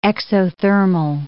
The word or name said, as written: exothermal